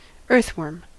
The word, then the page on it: earthworm: American English